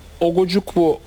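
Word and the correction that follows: Ugochukwu: olunca doğru mu telaffuz ettim? – Ugoçukwu hatta. – Leslie diye gitsin ya. – Herkes öyle diyor zaten. – Leslie. – Yani Leslie diye. – Doğru. – Ugoçukwu.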